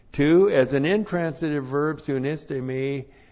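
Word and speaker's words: Soon iste me (συνίστημι): S-U-N, that's the preposition soon with. That's been prefixed to the verb histe me. Oop, what am I writing in Greek for? I-S. T, long E, M, I.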